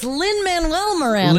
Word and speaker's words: Lin-Manuel Miranda: I'm like, is it Miranda Lin Manuel? Lin-Manuel Miranda. It's Lin-Manuel Miranda.